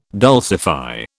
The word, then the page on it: dulcify: British English